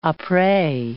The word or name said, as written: apres